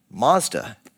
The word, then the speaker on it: Mazda: It's named after the founder Matsuda, that is pronounced Mazda in Japanese.